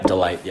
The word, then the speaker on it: Delite: So this was Delite. Am I pronouncing that correct? – Yeah, Delite.